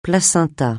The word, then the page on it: placenta: francais